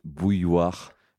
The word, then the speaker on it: bouilloire: It's bouilloire.